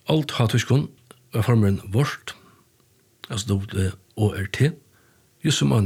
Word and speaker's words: waurd: Och stort lite är att agotiskon verda Waurd, W-A-U-R-D, men A-U uttala som Å, så her verda Waurd.